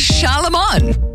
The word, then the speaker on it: Charlemagne: It's Charlemagne.